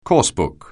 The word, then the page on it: coursebook: anglais